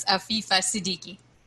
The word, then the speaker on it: Afifa Siddiqui: It's Afifa Siddiqui.